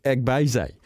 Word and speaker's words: Agbeze: 2:15, Ama Agbeze. Agbeze.